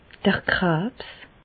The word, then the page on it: d'r Krabs: Bas-Rhin; Reichshoffen